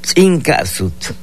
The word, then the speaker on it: Tzinkazut: Tzinkazut, escuche usted, es la pronunciación en lengua maya que ya regresé.